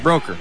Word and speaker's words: Broeker: Hey, we're going to have Brocker on mat 6 for Broker. – Mount Pleasant. Is it Broker or Brocker? – Broker. – Broker. It's spelled B-R-O-E-K-E-R.